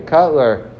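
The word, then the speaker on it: Kotler: So let's say in terms of, Rav Aaron Cutler, I don't know if you ever heard of him, he's one of the rabbis who started, let's say, the Lakewood Yeshiva, which is Cutler. Actually, Cutler.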